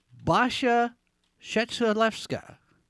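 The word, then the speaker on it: Basia Trzetrzelewska: And I wasn't quite sure how to pronounce her name until I heard Casey Kasem say it on America's Top 40. Basha Shetzelewska.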